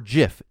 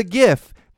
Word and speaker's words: GIF: If you're not aware of this, back in 2013, I believe it was, the creator of the GIF, The guy that created GIFs came out and said it's pronounced GIF with a hard G, not JIF like the peanut butter.